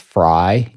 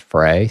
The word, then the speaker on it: Frey: So I was raised with it as Fry, even though we all knew it was supposed to be pronounced Frey. So I don't really give a shit which way it's pronounced.